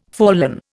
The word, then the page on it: fallen: Indian